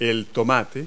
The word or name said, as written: tomato